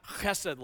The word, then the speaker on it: chesed: You and I really don't have chesed love. And by the way, if you didn't spit on the back of the head of the person in front of you, you didn't say it rightly.